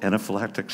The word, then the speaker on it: anaphylactic: And so he ends up going into, I believe it's called anaphylactic shock. I'm looking at the doctors. Yes, they said, that's it. That's how you pronounce that. He goes into anaphylactic shock.